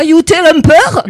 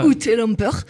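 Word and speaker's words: Ute Lemper: Alors je prononce très mal. – Ah oui, sûrement. – Là, il faut prononcer à l'allemande. – Alors je ne sais pas, Jutta Lemper.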